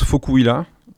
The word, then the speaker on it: Vokuhila: Normalement ça se prononce Fokuhila, et c'est un mot allemand.